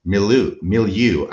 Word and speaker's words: milieu: This is all the mythology that was in circulation in Joseph Smith's milieu. I'm working on pronouncing that word correctly. Milieu, I think, is how I've been taught to say it.